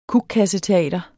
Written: kukkasseteater

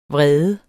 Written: vredes

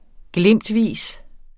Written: glimtvis

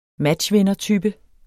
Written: matchvindertype

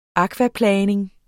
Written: akvaplaning

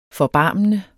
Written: forbarmende

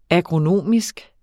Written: agronomisk

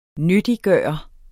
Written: nyttiggøre

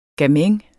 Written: gamin

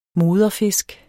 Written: moderfisk

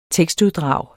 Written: tekstuddrag

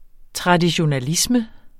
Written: traditionalisme

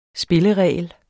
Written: spilleregel

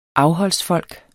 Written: afholdsfolk

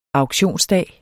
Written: auktionsdag

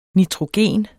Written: nitrogen

